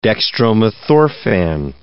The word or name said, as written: dextromethorphan